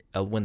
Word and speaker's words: Elwin: And this is also where we find out Ransom's first name, finally, in chapter 2, Dr. Elwin, is that how you pronounce it? Elwin, that's how I pronounced it, E-L-W-I-N.